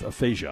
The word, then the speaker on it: aphasia: Number one for me was Bruce Willis stepping away from his acting career, diagnosed with aphasia.